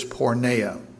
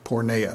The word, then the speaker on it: porneia: But the Greek word is porneia, porneia, and it's spelled P-O-R-N-E-I-A.